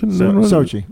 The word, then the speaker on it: Sochi: Sochi.